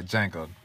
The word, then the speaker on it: Django: Sorry, Django. D is silent. I just like saying the D in there.